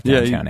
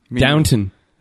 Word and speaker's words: Downton: All right, see, I know nothing about Downtown Abbey. – Downton.